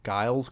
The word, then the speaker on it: Giles: It's G-I-L-E-S. Giles Corey.